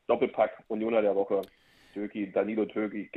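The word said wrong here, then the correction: Doekhi: Duki, Duki spricht man aus.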